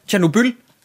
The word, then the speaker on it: Tjernobyl: Og drenge, jeg er rigtig glad for, at der ikke er nogen af os, der udtaler det forkert. Tjernobyl? Ja, Tjernobyl.